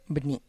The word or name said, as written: bni